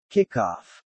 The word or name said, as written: kickoff